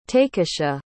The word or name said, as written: takisha